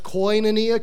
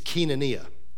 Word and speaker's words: koinonia: Now, you may have heard it pronounced koinonia because it looks that way in English, but in Greek, it's koinonia. So koinonia means fellowship.